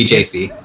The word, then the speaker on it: JC: We're both Catholic, that's the funny part, but yes, JC, the only JC. – It's pronounced like Jay-Z.